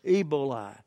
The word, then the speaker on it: Ebola: It's not emboli. How do you pronounce it? Yeah. How do you pronounce it? Ebola.